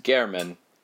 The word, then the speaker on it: Herman: Now that it's a sound film, we know that his name is pronounced German. It is definitely spelt Herman, but that explains why the subtitles in the silent version spelt it German because it is it's like a hard H, which I didn't know was a thing.